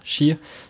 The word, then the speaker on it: Xia: Und den Chi-Fight, also Chi wird das ausgesprochen, ne? Wenn X-I-A, Chi.